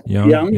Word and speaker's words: Young: Young, just like a young person.